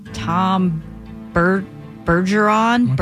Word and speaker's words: Bergeron: I can never say his last name right. Tom Bergeron? – You said it right.